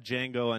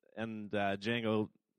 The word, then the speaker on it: Django: But if you did see it, you would know that the D is silent. And Django made a point to say it a lot.